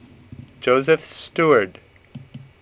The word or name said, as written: Steward